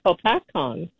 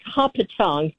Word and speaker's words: Hopatcong: And I remember when President Johnson decided they were going to drain Lake Hopatong. He mispronounced it because there was a drought.